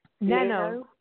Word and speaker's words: NaNoWriMo: I mean, didn't this kind of all start over the NaMo, am I saying it right? NaMo? – NaNoWriMo. – NaNo. – NaNoWriMo. – Okay.